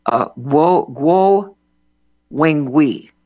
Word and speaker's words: Guo Wengui: Guo Wengui, G-U-O, W-E-N-G-U-I,